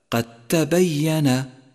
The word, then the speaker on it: قد تبين: قد تبين، فقد لونت الدال بلون الرمادي إشارة من مصحف التجويد إلى أنها لا تلفظ في حالة الوصل، فيصير النطق هكذا قد تبين. قد تبين، ومن الخطأ أن تقول قد تبين.